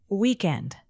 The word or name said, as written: weekend